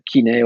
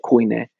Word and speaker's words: koine: kine or koine, depending on you pronounce it.